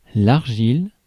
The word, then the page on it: argile: France; /aʁ.ʒil/